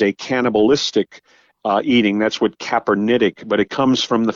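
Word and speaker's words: Capernaitic: Sometimes in theological works, you'll see the term, and I don't know if I'm pronouncing this right, Kaepernetic eating. – Exactly. – Is that right? – Exactly. That's exactly how it's pronounced.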